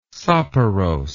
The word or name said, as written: soporose